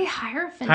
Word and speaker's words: Hierophant: Am I saying it right? Hierophant? – Hierophant? I say Hierophant. Like hierarchy, Hierophant.